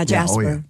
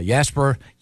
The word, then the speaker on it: Jasper: It's a hard J, though, right? – Yeah, Jasper. – Yeah, Jasper, Indiana, on Saturday, August 22nd.